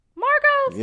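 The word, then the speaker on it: Marco's: I go to Macros. You know what I'm saying. – Marcos? – Yeah, there you go. – You know they said about a slice. – No, because look, because it's spelled like macro, like macro pizza. – Oh, it is. Marcos.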